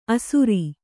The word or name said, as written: ಆಸರ